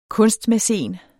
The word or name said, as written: kunstmæcen